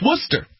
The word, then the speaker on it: Worcester: It's spelled like Worcester, but it's Worcester.